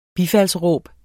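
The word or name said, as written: BIFALDSRÅB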